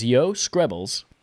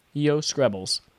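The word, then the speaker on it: Joe Skrebels: Starting with, the Xbox Series X velocity architecture should mean smaller game file sizes, less loading, and more, says Yo Scrabbles at IGN. It's a soft J in Joe. Yo Scrabbles.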